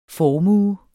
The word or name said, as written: formue